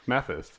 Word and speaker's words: Mathis: Mathis. That's how you say it.